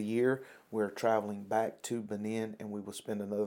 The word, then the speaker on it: Benin: So we're here, obviously, we've been talking to brother and sister from Bogalusa, Louisiana, but now you serve as missionaries in, help me pronounce the name. – Benin. – Benin. I always want to say B9 or something like that, but Benin, West Africa.